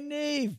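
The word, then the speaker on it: Niamh: Hey, Neve.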